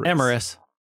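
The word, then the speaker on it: Amarys: Wait, how do we say her name? Amoris. – Amoris.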